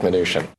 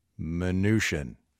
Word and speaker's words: Mnuchin: I'll go with Mnuchin. – Mnuchin.